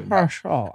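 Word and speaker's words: Paschall: Paschal.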